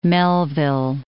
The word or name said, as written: Melville, Lake